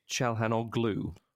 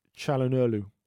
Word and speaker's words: Çalhanoğlu: How do we, because I pronounce it Çalhanoğlu, but is it one of these Erdogan or Gundogan sort of situations? – It's what the, yeah, it's what the Turkish call Umuşakge, the G with the little squiggle above it.